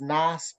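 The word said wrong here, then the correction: Naas: before when it's Nace. – Oh, my gosh. Nace.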